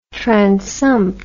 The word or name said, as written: transumpt